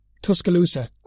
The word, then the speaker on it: Tuscaloosa: the absolute Thor thunder hammer fist from Tuscaloosa, Alabama.